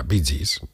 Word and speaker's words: Bee Gees: En su nuevo destino australiano, pues se empezaba a hablar de ellos, porque cantaban en programas de radio y ya se llamaban Bee Gees, que es la pronunciación inglesa de las iniciales BG, o sea, Bee Gees, de Brothers Jeff, y de ahí, pues eso, Bee Gees.